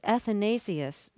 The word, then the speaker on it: Athanasius: You say it was Athanasius, am I saying his name right? – Yes.